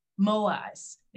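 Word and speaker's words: Moai: They call it, I think I'm saying this correctly, Moais. It's M-O-A-I-S, Moais, where they have groups of five friends that basically they commit to each other for life.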